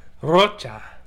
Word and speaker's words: Rocha: Well, you didn't roll your R either, so. – Chris De La Rocha. – Chris De La Rocha. – Rocha.